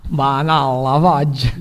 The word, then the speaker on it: Vanilla Fudge: Rieccoci in studio e dopo questa bellissima canzone dei Pink Floyd, tra un po' andremo ad ascoltare una canzone dei Vanilla Fudge. Se questa la pronuncia, ma penso di sì. – Vanilla Fudge. – L'ho controllata su internet e questa, anche se può sembrare un po' strano.